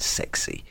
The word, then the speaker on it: XSXC: Scott, and that is X, S, X, C, pronounced sexy.